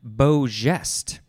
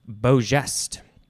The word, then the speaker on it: beau geste: It is beau geste. I think that's how it's pronounced. First word, B-E-A-U. Second word, G-E-S-T-E. And it looks like it's pronounced geste, because the pronunciation guide is spelled it Z-H-E-S-T.